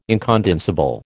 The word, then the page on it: incondensable: American English